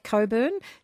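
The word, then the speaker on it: Cockburn: With a name like Coburn, spelt C-O-C-K-B-U-R-N, I have to be a little bit funny.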